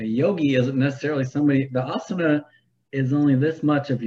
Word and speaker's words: asana: Um, I do find asana, is that how you say it? Okay, yeah, asana.